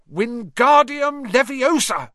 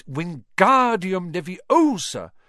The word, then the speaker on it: Wingardium Leviosa: "Wingardium Leviosa!" – he shouted, waving his long arms like a windmill. – "You're saying it wrong," Harry heard Hermione snap. "It's Wingardium Leviosa. Make the gar nice and long."